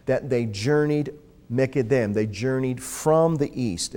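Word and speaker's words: Mekedem (מִקֶּדֶם): The Hebrew phrase here is Mekedem. And it's spelled from right to left, Mem, Kuf, Dalet, Mem. Again, Mekedem.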